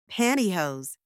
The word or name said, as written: pantyhose